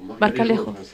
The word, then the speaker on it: Marjalejo: On est en train d'écouter Leslie Di et Tony Marjalejo. Non, Marjalejo. Comment on le prononce en français? – Marjalejo.